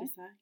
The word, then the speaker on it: Desai: The Dating Plan by Sarah Desai. Did I say that right? D-E-S-A-I? Desai? – Desai? Yeah, I would say Desai.